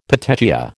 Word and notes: petechia: American English

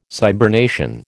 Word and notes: cybernation: American English

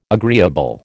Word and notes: agreeable: American English

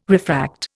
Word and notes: refract: Indian

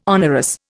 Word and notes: onerous: Indian

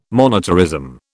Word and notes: monetarism: British English

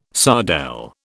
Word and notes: sardelle: British English

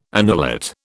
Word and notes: annulate: British English

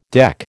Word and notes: deck: American English

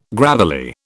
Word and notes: gravelly: British English

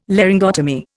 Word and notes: Laryngotomy: Indian

